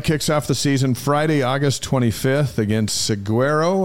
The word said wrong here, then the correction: Saguaro: Am I saying that right? Seguero? – Isn't it Seguero? – Seguero. – It's Seguero. Saguaro. – Saguaro cactus.